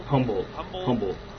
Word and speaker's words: Humboldt: So with that in mind, my special guest, is from Humboldt. Am I pronouncing this right? – Humboldt.